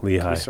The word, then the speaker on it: Lehigh: Would that be Lehigh? – Is it Lehigh? – I don't know. – Oh my God, I said it wrong. Lehigh.